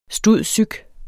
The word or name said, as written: stud.psych.